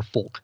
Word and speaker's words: folk: By the way, do you pronounce the L at all, or do you say folk or folk? – I'm a folk guy. – Yeah, me too. – Folk is what you eat dinner with.